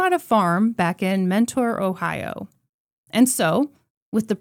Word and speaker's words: Mentor: Mentor spelled N-E. It's pronounced Menor. I knew it. – People, I'm going to spell this for you. Please be on my side. Mentor. – And that's literally how you spell the word mentor.